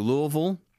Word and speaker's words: Louisville: Came back through Louisville, and that is how you say it. We asked.